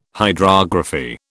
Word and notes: hydrography: British English

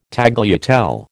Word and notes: tagliatelle: American English

